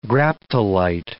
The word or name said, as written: graptolite